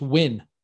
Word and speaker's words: Nguyen: I think that's pronounced Win. – It is Joseph Nguyen.